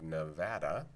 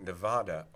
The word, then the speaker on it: Nevada: In this case, people were saying the official pronunciation should be something English, American, non-Spanish, that it's Nevada, not Nevada. Now, the state of Nevada, usually everyone there, as far as I understand, pronounces it Nevada. And the only people who pronounce it Nevada are outsiders from other states who are making an assumption about the pronunciation, like people who say Oregon instead of Oregon.